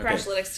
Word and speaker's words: Crashlytics: Crash Analytics team. Oh, did I say that wrong? – All right. – I got nothing here. – Just okay. Crashlytics team. – Crashlytics. Oh, yeah. I see what you did. You kind of removed the indefinite article.